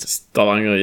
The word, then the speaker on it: Stavanger: So, uh, yeah, for everyone who doesn't know, uh, you of course are Werder Bremen fan from Stavanger. Is this how you pronounce it? Stavanger, yes. Stavanger, Norway.